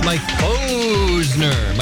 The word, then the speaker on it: Mike Posner: You don't remember how to say it. It's Mike Posner.